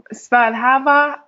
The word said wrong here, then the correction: Svadhyaya: I know exactly what you're talking about. Spell it for me one more time. – S-V-A-D-H-Y-A-Y-A. But it means study. – Svadhyaya. – Svadhyaya. – Svadhyaya. Mm-hmm. I believe that's right.